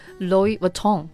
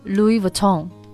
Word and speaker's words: Louis Vuitton: I'm not sure, but the right pronunciation should be Louis Vuitton. – 对、而且要注意这个地方的虽然它的拼写是 L-O-U-I-S、但是这个 S 是不要发音的 silent S、然后这个重音也要放在这个 tone 这里，所以其实它正确的读法应该是 Louis Vuitton.